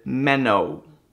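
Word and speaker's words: meno (μένω): The Greek word transliterated, meno. Okay? That's how you pronounce the Greek word in the English, meno, for abide.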